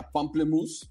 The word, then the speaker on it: pamplemousse: Aunque estoy viendo que se escribe diferente, se escribe Pompla Muse, como si fuera mousse de alce, pero suena justamente a pamplemousse, que pamplemousse en francés significa toronja.